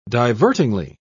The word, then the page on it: divertingly: British English